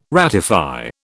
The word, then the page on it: ratify: British English